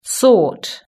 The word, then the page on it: sort: British English